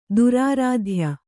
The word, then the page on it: ದುರಾರಾಧ್ಯ: durāradhya